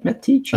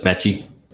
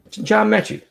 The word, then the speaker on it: Metchie: We're going to John, how do you say it, Metich? – I think it's just Metchie. – John Metchie, the third.